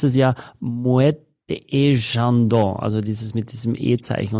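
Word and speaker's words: Moët et Chandon: Also du sagst dann Moete et Chandon so ist der, die richtige Aussprache, wenn du das, den ganzen Begriff, und wenn du den Rest dann weglässt, würdest du es trotzdem so aussprechen, als ob der Rest dann noch käme, ja, und deswegen würde ich immer Moet sagen.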